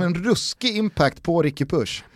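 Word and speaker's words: Puig: Så här står det, Catalan pronunciation, Ricard Push. Ruskigt mäta Push. Hur dåligt du uttalar ordet pronunciation. – Pronunciation. – Ja. Det var metakul, ja, men, att du inte kunde uttala just det ordet. – Ja, men här är faktiskt Wikipedia bra. Då har de skrivit då, katalanskt pronunciering. – Pronunciation. – Pronunciation är Ricard Push.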